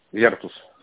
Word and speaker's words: Virtus: Virtus. На Е ударение.